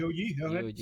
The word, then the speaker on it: GOG: Es, GOG, creo que es como lo se pronuncia. – Sí, GOG, sí. – Por eso es como suena.